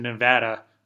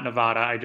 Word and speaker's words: Nevada: Note number two, Nevada is pronounced in Nevada, not Nevada.